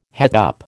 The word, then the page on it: het up: American English